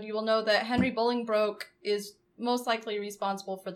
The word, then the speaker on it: Bolingbroke: We are talking about Henry IV, or Henry Bolingbroke. Did I say that right this time? – You did.